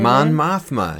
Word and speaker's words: Mon Mothma: And they go through a briefing with Mon Mothma. Is that how you say it? – Mon Mothma. Yes.